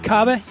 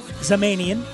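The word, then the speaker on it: Kaveh Zamanian: Kaveh. – You got it. – Zamanian.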